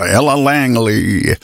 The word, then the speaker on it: Ella Langley: Och då säger man det med lite extra då amerikanskt uttal. Ella Langley.